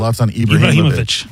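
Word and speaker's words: Zlatan Ibrahimović: How do you say his last name? – Zlatan Ibrahimovic. – Thank you so much.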